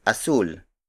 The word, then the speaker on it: asul: A more popular Tagalog word for blue is asul.